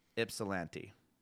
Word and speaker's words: Ypsilanti: So apparently it's a commonly mispronounced yip, but I think it's just ip. Mm. Ypsilanti.